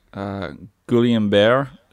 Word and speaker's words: Guillain-Barré: Yeah, he has a disease called, it's like French, I forget what it is, Guillain-Barré syndrome.